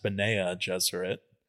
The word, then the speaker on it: Bene Gesserit: Specifically, and it's pronounced Benea Gesserit, but the Bene Gesserit.